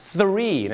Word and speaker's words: three: ก็คือทักษะที่จะใช้มันได้อย่างดีเยี่ยมหรืออย่างถูกต้องนั่นเองครับดังนั้นวันนี้นะครับเรามาดูดีกว่าครับว่าเรื่องราวต่างๆของตัวเลขกับภาษาอังกฤษจะมีอะไรบ้างครับแน่นอนครับสิ่งแรกเลยก็คือเรื่องราวของการออกเสียง pronunciation ครับ one two อันนี้ง่ายมากครับแต่คำว่า three ถ้าเป็นไปได้ก็อย่าลืมเอาลิ้นมาแตะฟันบนเล็กๆนะฮะ three นะฮะ three one two